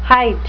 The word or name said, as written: hight